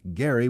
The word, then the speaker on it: Gerry: One of the oddities of the new creation is that for some time now it has been pronounced gerrymander with a soft G as in gentle, but the man for whom it is named pronounced his last name Gerry with a hard G as in go.